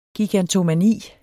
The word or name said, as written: gigantomani